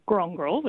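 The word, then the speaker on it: Kurongkurl: So Grongrol with the emphasis on the first syllable, and Gadigin, again, with the emphasis on the first syllable.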